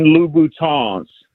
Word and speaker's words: Louboutin: And then most importantly, so the women that listen to the show don't start tagging you on YouTube, they are Christian Louboutins. You got to pronounce it with the French. – I'm Louboutin.